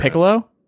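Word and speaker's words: Picollo: I think it'd be like Piccolo, like the is instrument. It Piccolo? – Like the little wood flute.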